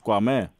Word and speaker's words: Κουαμέ: που είδα πρώτα απ' όλα ο καινούριος Κουαμέ. Το λέω το καλά. Εκεί τονίζεται. – Κουαμέ, ναι.